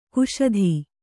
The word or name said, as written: ಕುಶದ